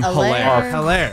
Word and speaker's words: Hilaire: I'm always going to call him Hilaire. – Of course. – Yeah, the H is silent. But okay, Hilaire. Whatever. But like, I think it's much better. – You're giving him a hard H.